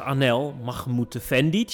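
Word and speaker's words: Anel Mahmutefendić: Met vandaag Anel Mahmutefendic. Zeg ik dat goed, Anel? – Je hebt het weer één keer goed.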